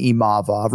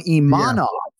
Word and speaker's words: Imavov: I just want to say this on the record for mispronouncing Nassourdine Imavov, right? Because I don't think anybody can pronounce this name correctly. I mean, you have worked so hard, Bry, yeah. and today you're you're going Imanov.